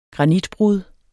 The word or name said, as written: granitbrud